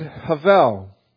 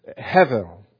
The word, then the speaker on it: hevel: He uses the Hebrew word havel. As I was lovingly, patiently instructed last week, I'm saying it wrong. I'm going to just butcher it. Hevel.